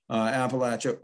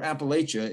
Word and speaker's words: Appalachia: There's a lot of different attitudes about Appalachia, or Appalachia, if you grew up in New York, as I did, especially how you pronounce it in the first place.